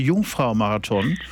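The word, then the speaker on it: Jungfrau-Marathon: Wat is de Jongvrouwmarathon? – Ja, dat spreek je heel goed uit.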